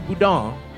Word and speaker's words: Boudin: And while the French pronunciation of the family name is closer to Boudin, locals say it the anglicized way, the American way.